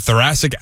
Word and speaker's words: thoracic: The Yankees thought that he might have thoracic... I don't even know how to pronounce it. Do you know how to pronounce that? – Yeah, thoracic outlet syndrome.